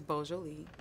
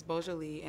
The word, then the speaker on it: Beau’Joli: It's B-E-A-U apostrophe J-O-L-I. – Okay. – Just in case y'all want to know how to spell it, because some people like to put B-O, but it helps them pronounce it. But it's Beau’Joli, and the meaning means spiritual, I'm sorry, beautiful soul.